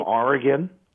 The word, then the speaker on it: Oregon: Joy, was Patty, did you say, right, from Oregon? – It's Oregon in Wisconsin. Oregon is the state, but that's Oregon is how we pronounce the community near Madison. – O-R-E-G-O-N? – That's right.